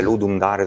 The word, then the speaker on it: Ludum Dare: Si scrive Ludum Dare esattamente così, si pronuncia in questo modo alla latina, non so.